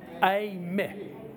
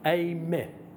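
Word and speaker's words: Eimi (εἰμί): Okay, the combination of epsilon and iota is pronounced like the A in fate. So, altogether, ego, Eimi, Eimi, ego, Eimi.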